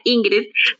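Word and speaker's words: Yngrid: ah, para los que no saben, bueno, mi nombre Ingrid. Para todo el mundo se escribe con I latina al principio, pero mis padres no sé qué, qué les pasó, que tuvieron y me pusieron con Y al comienzo.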